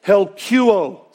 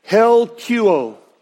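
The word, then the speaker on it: helkuo (ἑλκύω): is pronounced helkuo. Say that with me. Helkuo.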